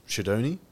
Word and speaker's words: Sciaudone: Sciaudone? – Yeah, that's correct.